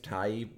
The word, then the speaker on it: Tighe: Kevin Tighe, I think is how you pronounce it. Tighe. I think it's Tighe.